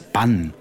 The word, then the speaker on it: Bann: Bahn, B-A-H-N. Now, there's a similar word that means hex, that's Bann, B-A-N-N. – Two N's, so it's a short vowel sound. – Yes, it has double consonants. N-N, B-A-N-N, Bann versus B-A-H-N, Bahn.